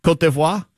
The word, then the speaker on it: Côte d'Ivoire: Like right now, Ivory Coast, you want to go with their French pronunciation there, Bo? Côte d'Ivoire? – There you go.